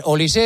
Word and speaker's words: Olise: Claro, si no lleva acento sería Olis. – Es Olis. – ¿No? Eso me ha dicho Pablo Polo de Marca.